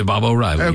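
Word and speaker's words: Baba O'Riley: 2, Baba O'Reilly. Did I pronounce that right? The Who? – Yeah, Baba O'Reilly.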